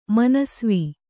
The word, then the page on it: ಮನಸ್ವಿ: manasvi